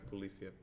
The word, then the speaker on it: Polyphia: Como é que se escreve Polífia para quem quiser procurar? – Ah, é P-O-L-Y-P-H-I-A, acho que é.